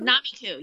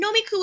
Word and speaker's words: Nomiku: Is that how you pronounce Nomiku? – Namiku, yeah. – Namiku. – I mean, Nomiku would be the correct Japanese way. And it is a Japanese word, nomikui, which means eating and drinking in Japanese. But we Americanize it because we like the word nom. It's N-O-M-I-K-U.